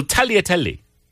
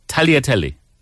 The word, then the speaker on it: tagliatelle: Well, tagliatelle is a double L. – What? – Tagliatelle. Tagliatelle. – What's that? – The noodle. – Oh. – The Italian pasta noodle. – Oh, and they pronounce it. – Yeah, they pronounce the double L.